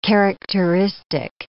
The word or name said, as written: characteristic